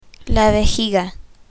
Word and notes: vejiga: spanish

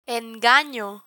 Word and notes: engano: Spanish

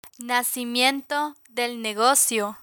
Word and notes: nacimiento del negocio: Spanish

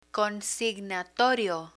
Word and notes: consignatorio: Spanish

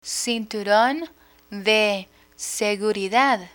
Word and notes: cinturon de seguridad: spanish

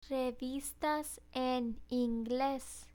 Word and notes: revistas en ingles: spanish

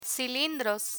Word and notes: cilindros: spanish

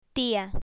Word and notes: tia: spanish

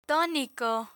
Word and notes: tonico: spanish

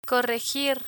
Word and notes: corregir: spanish

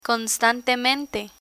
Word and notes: constantemente: Spanish

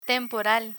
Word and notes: temporal: spanish

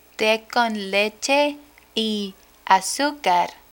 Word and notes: te con leche y azucar: spanish

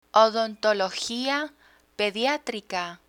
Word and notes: odontologia pediatrica: spanish

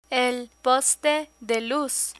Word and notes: poste de luz: Spanish

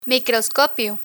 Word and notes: microscopio: Spanish

